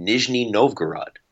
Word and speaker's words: Nizhny Novgorod: I just made hotel reservations for 4 nights in Nizhny Novgorod.